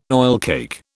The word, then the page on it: oilcake: British English